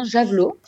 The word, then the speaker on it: Javelot: Alors, euh, alors déjà, en effet, on, prononce bien Javelot. – D'accord. – Euh, l'idée étant de, par Javelot d'avoir un nom qui, soit prononçable dans toutes les langues. Donc, euh, on le prononce vraiment comme il s'écrit.